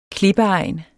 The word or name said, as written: klippeegn